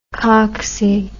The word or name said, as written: coxy